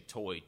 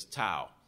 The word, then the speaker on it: Toi: Now, your translation may say Toi, T-O-I, but the pronunciation of this guy's name is Tou.